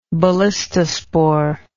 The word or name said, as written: ballistospore